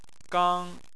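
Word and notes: gang1: tone 1

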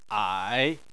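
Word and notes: ai: tone 3

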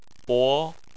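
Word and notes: bo: tone 2

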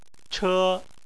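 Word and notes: che1: tone 1

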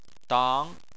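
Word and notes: dang: tone 2

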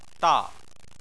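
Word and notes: da: tone 4

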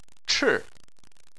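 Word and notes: chi4: tone 4

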